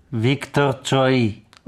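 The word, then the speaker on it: Viktor Choi (Виктор Цой): Viktor Choi.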